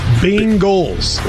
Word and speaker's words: Bengals: The Bengals. – I don't say the Bengals. I say it like people in Cincinnati say it. Bengals. – But there's no I. It's Bengals.